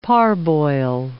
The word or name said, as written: parboil